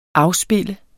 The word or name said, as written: afspille